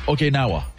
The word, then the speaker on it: Okinawa: Okinawa.